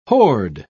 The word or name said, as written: horde